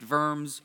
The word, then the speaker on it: Worms: Now, in this context, diet simply means council, and worms is actually probably pronounced verms, but whatever.